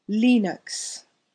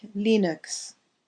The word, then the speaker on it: Linux: Linux operating system is pronounced with a short I like E, and the first syllable is stressed, as in Linux. Repeat Linux.